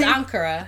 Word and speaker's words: Ankara: It's Ankara.